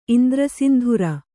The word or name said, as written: ಇಂದ್ರಸಿಂಧುರ